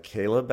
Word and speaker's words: Chleb: Well, so his name is Caleb Ravenel, although it's spelled C-H-L-E-B. So it's like Chleb. Chleb Ravenel, but it's like Caleb, actually. Caleb Ravenel.